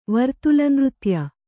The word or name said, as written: ವರ್ತುಲನೃತ್ಯ